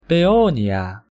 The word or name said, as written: paeonia